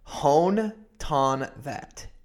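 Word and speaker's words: Hoan Ton-That: It was developed by an Australian tech mogul called Hone Ton That. H-O-A-N and then T-O-N-T-H-A-T.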